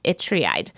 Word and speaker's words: Ytreeide: The next resource I would like to tell you about is a series of 4 Advent storybooks by the author Arnold Itreide, and it's spelled Y-T-R-E-E-I-D-E. It's pronounced Itreide.